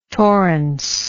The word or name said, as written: torrence